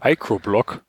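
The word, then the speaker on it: Micro-Block (μBlock): was, was, was ein kleines U im Namen hat, wird Micro ausgesprochen. Micro-Block? – Ja.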